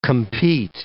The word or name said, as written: compete